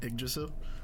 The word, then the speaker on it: Yggdrasil: Yggdrasil? – Yeah.